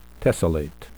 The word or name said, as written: tessellate